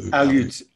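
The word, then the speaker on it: Aleut: Alut, am I pronouncing that correctly? Alut kayaks? Is Alut the right? – Yes, Alut.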